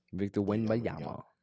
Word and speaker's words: Victor Wembanyama: Victor Wembanyama. It's fine. You got the pronunciation.